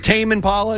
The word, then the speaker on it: Tame Impala: Tommy Impala, am I saying that right? Is it Tame Impala? It's spelled Tame.